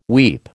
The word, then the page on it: weep: American English